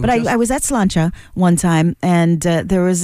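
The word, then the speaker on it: Sláinte: I remember I was at a bar one time called Slancha. – Mm-hmm. – Or slaint, as it's spelled. Don't ever say that.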